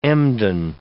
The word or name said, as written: Embden